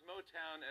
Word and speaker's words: Motown: Later on, Trump said, Motang, of course, began in the great city of Detroit. – Right after that, Yosemite National Park was like, damn, you too? Yeah, Trump mispronounced Motown as Motang.